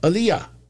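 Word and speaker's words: Ilja: Walter versus Ia Dragunov. Did I say that right? See, I got to get used to it now. Ilya.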